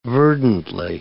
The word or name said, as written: verdantly